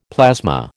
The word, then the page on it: plasma: American English